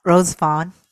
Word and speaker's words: Rosevonne: Rosevonne, yeah.